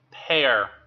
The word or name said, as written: pare